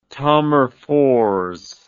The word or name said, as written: Tammerfors